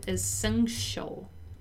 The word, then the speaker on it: essential: The pronunciation of the word essential is interesting to notice too. Once again, that T has the sound of a Portuguese X, right? So essential, essential, right?